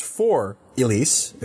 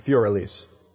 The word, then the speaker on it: Für Elise: So this one, I think, would actually be pronounced for Elise if we were saying it in English, but in 1810, this German dude called it Für Elise.